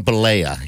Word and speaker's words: Bollea: His name is Terry Gene Bollea, yeah.